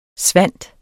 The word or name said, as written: svind